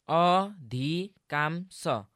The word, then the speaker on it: अधिकांश: गुरु, आँगन भन्नुपर्नेमा आँगन अनि अधिकांश भन्नुपर्नेमा अधिकांश भने। – हो त, यसलाई भने जस्तै आँगन आँगन र अधिकांश अधिकांश भनेर उच्चारण गर्नुपर्छ।